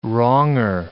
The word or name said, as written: wronger